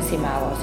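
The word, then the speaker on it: SCImago: no se evalúan por los famosos cuartiles de Simago. Simago se escribe s SCI Mago.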